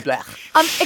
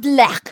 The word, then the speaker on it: blech: B-L-E-C-H. Blech. – Right, blech. – Excuse me, Martin. It's not blech. It's blech.